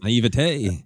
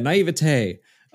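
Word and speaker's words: naïveté: How do you say that word? – Naivete. – Yeah, naivete.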